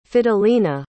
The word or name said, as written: fidelina